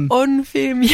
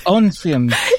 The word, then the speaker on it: Onfim: And I think, just tell us about, I don't know how to pronounce the name, Onfim? – Oh, little Onfim, yes. – Onfim.